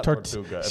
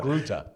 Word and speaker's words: Togruta: It's not Tortuga. – She's a Tortuga. – Togruta.